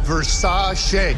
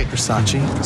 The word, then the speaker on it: Versace: Pronounced Versace. – Versace.